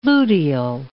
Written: luteal

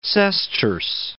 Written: sesterce

